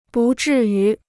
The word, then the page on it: 不至于: bù zhì yú